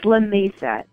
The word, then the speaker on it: La Mesa: Is it La Mesa or La Mesa? – It's La Mesa.